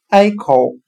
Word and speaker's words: echo: 今天所学的单词 “echo” 回声，我们就可以通过它的发音来联想到汉语的“ 挨口”，挨着山洞口向里面喊话，你就会听到你的回声了。